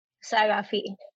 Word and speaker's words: Saghafi: Okay, so I'll say it slow. Firuze Saghafi. – Firuze Saghafi. – Firuze? – I get so many attempts. That's a good one. That is a very good one. That's exactly how you say my name.